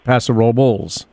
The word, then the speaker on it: Paso Robles: So you do the Bulls, Paso Robles.